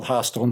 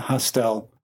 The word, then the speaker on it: Hostel: And that's how you say it, by the way. It's called Hostel, not Hostel.